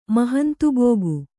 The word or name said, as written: ಮಹಂತುಬೋಗು